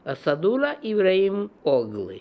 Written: Assadulla Ibrahim Ogly

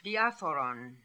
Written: Diapharon

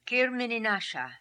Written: Kirmininasha

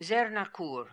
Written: Zernakoor

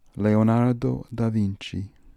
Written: Leonardo da Vinci

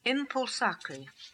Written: Impulsakri

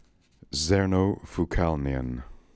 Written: Zernofookalnian

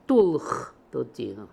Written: Toolkhtotino

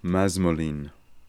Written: masmolin

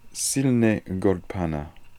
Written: silnegordpana

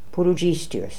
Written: Pooloodjistius

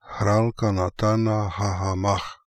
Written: Khralkanatanakhakham

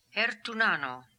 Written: Hertoonano

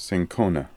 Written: Cinchona

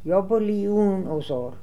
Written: iabolioonozor

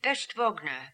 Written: peshtvogner

